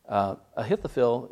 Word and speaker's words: Ahithophel: Ahithophel is pronounced Ahithophel. Ahithophel is how it's pronounced.